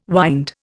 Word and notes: wynd: Indian

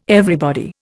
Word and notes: everybody: Indian

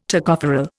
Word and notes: Tocopherol: Indian

